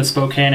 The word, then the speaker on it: Spokane: So if anyone listening has a job in the Spokane area for a geologist... – Well, thanks for letting us know it's pronounced Spokane. I thought it was Spokane or something.